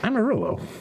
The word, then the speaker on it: Amarillo: Or Amarillo. How do I say that? – Amarillo. Just like Armadillo.